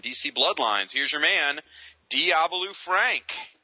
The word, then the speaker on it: Diabolu Frank: Oh, God, you actually got it right. Oh, man. See, I was looking forward to sound like Doobie-Doo Frank something bizarro, but you actually pronounced it correctly this time.